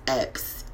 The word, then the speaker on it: Epps: And the last name is Epps, E-P-P-S.